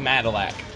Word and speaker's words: Madillac: Dude, no, it's Raven Madillac. Dude, rhymes with Cadillac.